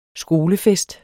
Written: skolefest

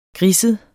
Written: griset

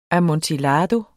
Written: amontillado

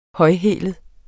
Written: højhælet

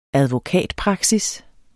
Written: advokatpraksis

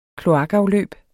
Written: kloakafløb

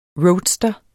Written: roadster

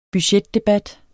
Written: budgetdebat

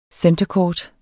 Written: centrecourt

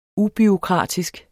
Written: UBUREAUKRATISK

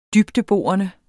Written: dybdeborende

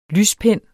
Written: lyspen

